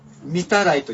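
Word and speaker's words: 御手洗: そしてお手洗い、トイレのお手洗いという漢字で見たらいと読むんだそうです。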